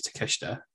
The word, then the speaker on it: Takeshita: Well, apparently, yeah, we couldn't pronounce his name last week. We've been reliably informed that it is Takeshita.